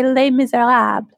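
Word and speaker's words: Les Misérables: but you talk about a book called Less Miserables, and I'm probably not saying that right. – Yeah. It's French. So if you want to be very French, you say Les Miserables.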